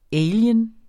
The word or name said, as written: alien